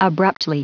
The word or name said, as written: abruptly